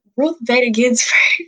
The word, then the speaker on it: Ruth Bader Ginsburg: And I feel like with Ruth Gator Benz. – ooh, Ruth. I forgot how to say her. – Ruth Bader Ginsburg.